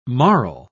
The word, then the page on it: marl: British English